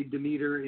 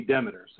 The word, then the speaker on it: Demeter: Well, Demeter is the Greek goddess of agriculture. And in Europe, they say Demeter. In the U.S., for some reason, we say Demeter. So we all use that Demeter pronunciation.